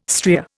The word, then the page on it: stria: Indian